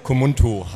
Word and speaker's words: Communtu: Das ist ja schon, ich habe es ja gerade schon falsch ausgesprochen. Ja. es vielleicht nochmal richtig oder erklär mir mal, was das überhaupt ist. Kumuntu heißt es.